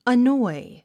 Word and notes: annoy: ənɔ́i; アノイ

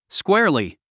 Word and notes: squarely: skwéərli; スクェアリィ

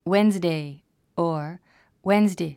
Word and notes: Wednesday: wénzdei; ウエンズデイ

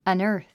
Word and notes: unearth: ʌ̀nə́ː r θ; アナァス

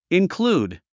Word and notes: include: inklúːd; インクルード

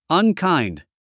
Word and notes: unkind: ʌnkάɪnd; アンカインド

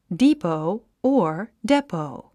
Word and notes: depot: díːpou; ディーポウ

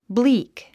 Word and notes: bleak: blíːk; ブリーク

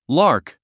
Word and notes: lark: lάː r k; ラーク

